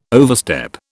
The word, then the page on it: overstep: British English